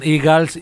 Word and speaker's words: Eagles: Yo he decidido que, con mi conocimiento del inglés, se pronuncian Eagles y punto.